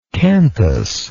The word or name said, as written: canthal